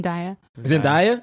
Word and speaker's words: Zendaya: Zendaya, is that how you say her name? – Zendaya.